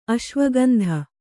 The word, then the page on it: ಅಶ್ವಗಂಧೆ: aśvagandhe